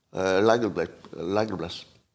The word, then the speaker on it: Elagabalus: What is the proper pronunciation of that name? Elagabalus. It's like that. It's best if you say it like you're drunk, because then nobody's going to ask too many questions.